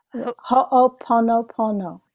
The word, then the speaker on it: Ho'oponopono: Ho'oponopono.